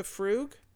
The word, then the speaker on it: Frug: But she goes on to say, which he pronounced as if it rhymed with rug, which is just how I pronounced it, rather than moog. – Like the frug? – M-O-O-G. Moog, the frug.